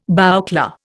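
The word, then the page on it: bouclé: Indian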